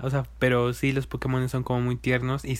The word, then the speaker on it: Pokémon: Es Pokémon o Pokémon o Pokémon. – Eh, es Pokémon. – Ajá, Pokémon. Ah, de, hecho lleva, un acento en la E.